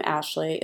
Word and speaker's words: Ashley: It's double plated, and my name, Ashley, is spelled A-S-H-L-E-Y.